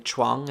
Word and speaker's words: Chuang: A helpful patron in the chat says that the CH in Chuang is pronounced like cheap and that Chairman Xi is pronounced like she.